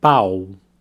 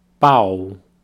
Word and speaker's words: pau: Repeat after me the word for dick, pau, pau. Very open a ah sound there, pau.